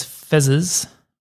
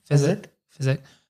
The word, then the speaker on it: Fezzik: Andre the Giant as Fezzes. Is that how you say his name? – Fezzik.